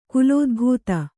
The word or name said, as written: ಕುಲೋದ್ಭೂತ